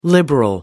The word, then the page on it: liberal: American English